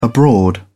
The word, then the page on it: abroad: British English